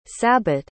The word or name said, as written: sabbat